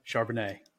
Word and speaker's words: Charbonnet: Charbonnet.